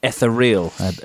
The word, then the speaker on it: ethereal: Ethereal, I believe it's pronounced.